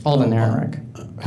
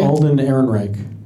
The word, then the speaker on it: Alden Ehrenreich: Alden Ehrenreich. – How do you say it? – Alden Ehrenreich. – Ehrenreich.